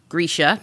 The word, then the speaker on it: Grisha: It's adapted from the Grisha trilogy, pronounced Grisha.